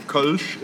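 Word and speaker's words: Kölsch: So it's Kolsch is the actual pronunciation, but I'd say most people just call it Kolsch.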